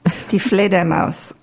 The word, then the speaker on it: Die Fledermaus: Die Fledermaus Overture.